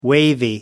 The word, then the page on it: wavy: American English